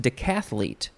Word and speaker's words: decathlete: It's just decathlete. I thought there would be an extra syllable in there.